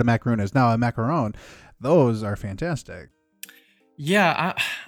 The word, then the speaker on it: macaron: Uh, number one for me is uh, the popularly mispronounced dessert, uh, macaron. – Oh, not a macaroon. – Not a macaroon because that is coconut based.